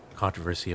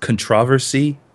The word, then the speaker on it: controversy: There's a lot of controversy about, you know, because PC has more accuracy than I believe it's pronounced controversy. – Controversy.